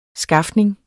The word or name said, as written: skafning